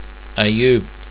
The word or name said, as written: ayoub